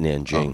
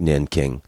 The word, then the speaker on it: Nanjing: They pronounce it Nanjing. – Okay. – But it looks like Nanking.